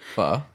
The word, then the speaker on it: pho: I had the seafood pho. Is that, I think I pronounce it? – Pho.